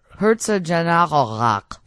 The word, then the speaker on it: Herzogenaurach: It was there in a village by the name of Herzogenaurach. – How do you say that again? – Herzogenaurach. – That's better. That sounds, the first time didn't sound right, but that time sounded right.